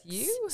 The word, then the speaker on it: Siew: So Dr. Eric Siew.